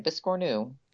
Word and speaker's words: biscornu: biscornu. Is that how you say it? – That is how you say it, biscornu.